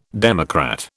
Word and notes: democrat: British English

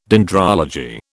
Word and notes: dendrology: British English

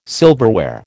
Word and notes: silverware: American English

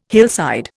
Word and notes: hillside: Indian

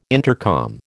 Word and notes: intercom: American English

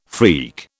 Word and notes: freak: British English

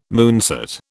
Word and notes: moonset: British English

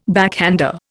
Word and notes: backhander: Indian

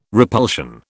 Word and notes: repulsion: British English

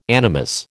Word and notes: animus: American English

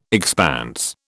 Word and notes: expanse: British English

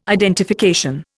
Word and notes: identification: Indian

